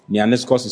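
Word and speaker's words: Neaniskos (νεανίσκος): Neaniskos is spelled N E A N I S K O S.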